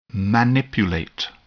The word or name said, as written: manipulate